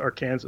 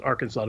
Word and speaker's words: Arkansas: But they're pronounced differently by Arkansas law. It's not pronounced Arkansas. It's pronounced Arkansas to make it more like the French pronunciation.